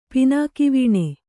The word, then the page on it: ಪಿನಾಕಿವೀಣೆ: Kannada